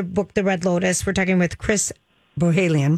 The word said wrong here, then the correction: Bohjalian: Bohalian, and I always want to pronounce the J, Chris. Bohalian. – Well, you do, you do. It's actually, it is Bojelian.